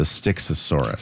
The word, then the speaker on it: Styxosaurus: I had to Google how to say it. It's a Styxosaurus.